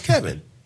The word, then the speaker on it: Ceven: Spell your first name. – C-E-V-E-N. – And it's pronounced Kevin.